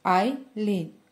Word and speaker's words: Айлін: Вибач, Айлін, я б мала уточнити щодо вимови ще до початку дискусії. Айлін, кажу я повільно і правильно, оскільки відчуваю, що маю сказати бодай щось.